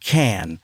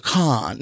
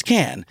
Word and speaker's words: Cannes: When I was in Cannes and it is pronounced Cannes. – Yes. – I was like, Cannes. No, it's Cannes.